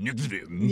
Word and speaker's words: NXIVM: Is that how you pronounce it? Nexium? – Like the purple pill. – Right. Mm-hmm. It's spelled N-X-I-V-M. Nexium.